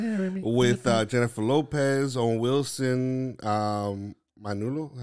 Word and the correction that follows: Maluma: How you pronounce that guy's name? Maluma. Maluma. Right.